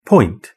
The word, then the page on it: point: British English